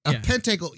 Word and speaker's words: pentacle: Or as Jack calls it, a pentangle. – pentacle. Yeah. So did anyone look that up? I was too lazy to do that. – He just pronounced it wrong.